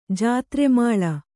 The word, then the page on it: ಜಾತ್ರೆಮಾಳ: jātre māḷa